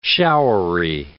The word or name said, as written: showery